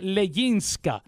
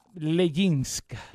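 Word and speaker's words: Leginska: Mi nombre es Leyinska. Ah, ajá, exacto. Se pronuncia Leyinska y se escribe con G.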